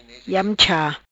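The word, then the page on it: Iam-Cha: patua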